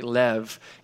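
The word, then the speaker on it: lev (לֵב): I looked up in the Hebrew, and it's the word, it's L-E-B, but it's actually pronounced with a V, like lev, and the root word is levav.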